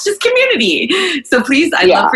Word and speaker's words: Qmooniti: And a lot of people don't know how to pronounce it because it's spelled Q-M-O-O-N-I-T-I, but it's just the same way you pronounce C-O-M-M-U-N-I-T-I, right? It's Community.